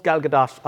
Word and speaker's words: Gal Gadot: No, it's Gal Gadot. I've asked her, trust me.